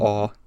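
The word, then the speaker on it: Å: It's just like ah. – This is pronounced ah.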